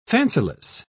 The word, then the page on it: fanciless: British English